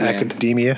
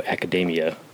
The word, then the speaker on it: Academia: If that's even how you say it, it's spelled not like Academy. It's got a M-I-A at the Academia. – End. Is that what it is? – Yeah. – Yeah. All Might calls it, Hero Academia.